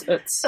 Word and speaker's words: Utz: Is that how you pronounce it? – Yeah. It's Utz.